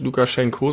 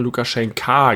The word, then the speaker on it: Lukaschenka: Ja, aber du hast korrekterweise unsere Folge nicht Lukaschenko, sondern Lukaschenka genannt. Lukaschenko ist, glaube ich, die russische Aussprache oder so. Ja, er heißt also eigentlich Lukaschenka.